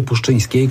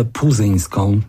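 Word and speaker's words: Puszczyńska: żeby słynny prezenter kaneńskiego Czerwonego Dywanu nauczył się wymawiać jej nazwisko, bo jak na razie wprowadzają ją jako Ewę Puzyńską.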